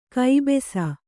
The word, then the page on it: ಕಬ್ಬಸು: kannada; kabbasu